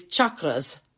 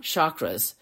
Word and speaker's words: chakras: And the first thing you need to know is the traditional pronunciation is chakras, not chakras. Chakras is the American pronunciation,